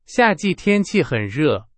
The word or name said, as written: 夏季